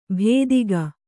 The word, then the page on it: ಭೇದಿಗ: bhēdiga